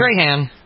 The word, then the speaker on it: Strahan: Is that how you pronounce his name, Strahan? – Strahan.